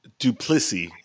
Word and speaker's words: Du Plessis: And we got your boy, Sean Strickland, taking on Dricus to Pussy. – Duplissy. You didn't say his name right. – His name is Duplissy.